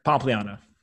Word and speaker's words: Pompliano: Pompliano, is that the right way to say it? – Close enough, yeah. – No. So say it how you would say it. – Pompliano. – Pompliano. Okay, great.